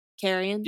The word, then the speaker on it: carrion: That's how it's pronounced? Carrion? – Yeah.